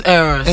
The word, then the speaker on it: Eros: Eros, I'm not really getting the pronunciation well, but then... – It's Eros. – Eros, you're good.